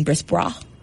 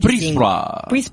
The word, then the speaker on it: Brisebois: I think the biggest thing to watch with the Lightning, in my opinion, is Julien Brisebois. Mm-hmm. I totally butcher that, but Brisebois. – God, that was terrible.